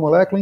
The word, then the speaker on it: Molecoola: É, a gente deve nas próximas semanas começar essa iniciativa aí com a molécula, inclusive com apoio do pessoal que segue a gente no Instagram. Siga o nosso Instagram, Molécula Oficial. Molécula se escreve trocando U por dois Os.